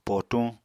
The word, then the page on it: potuñ: apatani